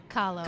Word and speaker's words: Kahlo: Kahlo.